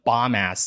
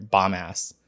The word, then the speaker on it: Bombas: Is that how you pronounce it? – Probably not. Probably bombas. – Yeah, I was like, wait a minute, I don't think so. – There's only one S at the end.